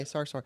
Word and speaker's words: Sarsour: how do you say her name? Sarsour? Okay, Sarsour.